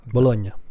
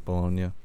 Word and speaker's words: Bologna: It's like Bologna, right? – Bologna. – Bologna.